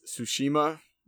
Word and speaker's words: Tsushima: It's Tsushima, I believe, is how they pronounce it.